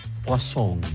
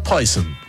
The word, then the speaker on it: Poison: y la ciútica de la vendedora lo pronunciaba Poisson. Poisson, no Poison, Poisson, así le decía la vendedora, ciúticamente, según Lipe, porque había comprado ese perfume Poison.